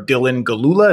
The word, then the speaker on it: Dylan Gelula: Dylan Gelula, is that right? Yes, that's right.